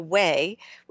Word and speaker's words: OUAI: You can find out more and they have a whole line of body products as well at The OUAI, which is spelled T H E O U A I dot com and use code WISE Health for 15% off your entire purchase.